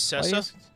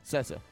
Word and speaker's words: Cessa: Luis Cessa. – Cessa.